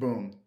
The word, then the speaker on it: Buium: One of them from Carter Mazur, that was with 7 minutes left in the third, and then one of them by Shea Bui. – I think it's just Boom. – Boom. – Yeah, I'm pretty sure. – It's spelled B-U-I-U-M. So yeah.